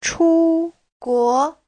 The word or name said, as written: chu1guo2